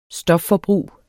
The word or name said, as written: stofforbrug